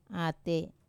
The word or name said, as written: ate